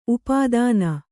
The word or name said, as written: ಉಪಾದಾನ